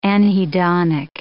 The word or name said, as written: anhedonic